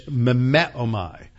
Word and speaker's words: mimeomai: In the New Testament, the verb form of the noun mimetes is mimeomai. Mimeomai. That's spelled M-I-M-E-O-M-A-I.